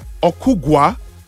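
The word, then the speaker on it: Ogugua: My name is Ogugo from Jabi. – Ogugo. Wow. Beautiful name. – Okugwa. O-G-U. – Okay, beautiful. You're spelling it for me. O-G-U. – G-U-A. – G-U-A.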